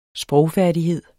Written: sprogfærdighed

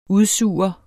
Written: udsuger

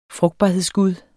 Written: frugtbarhedsgud

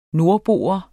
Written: NORDBOEN

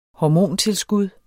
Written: hormontilskud

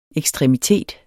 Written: EKSTREMITETS

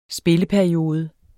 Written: spilleperiode

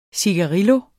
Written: cigarillo

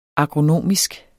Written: agronomisk

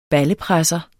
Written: BALLEPRESSER

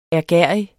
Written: ærgerrig

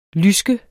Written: lyske

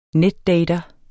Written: netdater

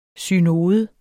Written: synode

